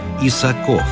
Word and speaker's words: Исаков: Хотя отец будущего адмирала требовал ударения на последнем слоге «Исаков».